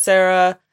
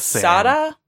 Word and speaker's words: Sara: So he texts him as Sarah, and it's spelled S-A-R-A, so in my mind I just keep saying Sarah. – Yeah, it's Sada.